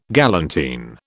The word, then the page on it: galantine: British English